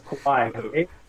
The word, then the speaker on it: Kawhi: Kawhi.